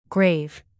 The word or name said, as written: grave